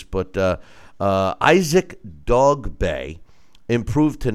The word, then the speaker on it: Dogboe: I, I, thought it was Dog Bow.